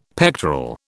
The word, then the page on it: pectoral: British English